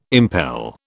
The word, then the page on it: impel: British English